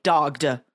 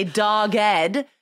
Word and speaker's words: dogged: And notice that we do pronounce the E there. We don't say dogged. We say dogged, and it's often coupled with the word determination, like dogged determination.